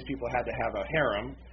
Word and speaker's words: harem: harem, or harem is the way you pronounce it, if you're doing it in Arabic or close to Hebrew, and, you know, make her one of his wives.